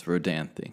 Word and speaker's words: Rodanthe: It Rodanthe? It's Rodanthe. – I thought it was Rodanthe. – I've never seen Knights in Rodanthe, but I just remember that movie title because it was always on sale at the movie gallery.